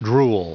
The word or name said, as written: drool